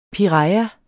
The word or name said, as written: piraya